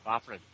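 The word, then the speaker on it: Fafhrd: And it is Fofford and Grey Mouser. Did I say that right? Fofford? – Yeah, Fofford, yeah.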